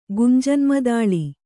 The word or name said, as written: ಗುಂಜನ್ಮದಾಳಿ